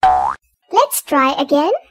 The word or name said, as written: Let's try again